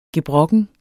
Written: gebrokken

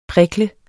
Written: prikl

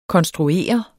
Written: konstruere

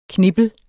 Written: knippel

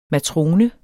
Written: matrone

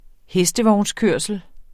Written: hestevognskørsel

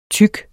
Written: tyk

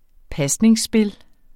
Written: pasningsspil

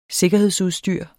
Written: sikkerhedsudstyr

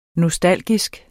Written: nostalgisk

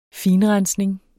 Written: finrensning

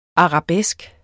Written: ARABESKEN

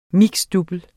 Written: double

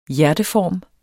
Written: hjerteform

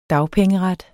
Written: dagpengeret

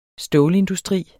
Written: stålindustri

